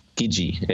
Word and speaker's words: Gidgee: Gidgee, yeah. – How do you spell that? – G-I-D-G-E-E.